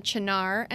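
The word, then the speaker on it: Cynar: It's called a Chinar and Soda. And Chinar is a bittersweet artichoke-based liqueur. And don't be scared. – How do you spell that? – So it's C-Y-N-A-R.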